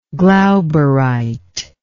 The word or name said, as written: glauberite